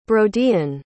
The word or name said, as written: broden